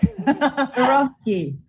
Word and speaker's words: Swarovski: So for you, most of you, an international publicist, and she was the one that made Swarovski. Did I pronounce it right? – Swarovski.